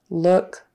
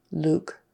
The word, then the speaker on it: look: Uh, look, book, took, shook. Um, and she's pronouncing it more of an ooh, ooh long U, Luke. It should be look.